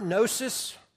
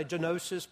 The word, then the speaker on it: gnosis: All right, Gnosticism comes from the Greek word gnosis. It has a G in front of it, so some people say genosis, but just don't, you don't pronounce the G, which means to know.